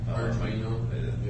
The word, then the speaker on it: Arduino: and he's got it's pronounced Fadrino. – Arduino, whatever that's called.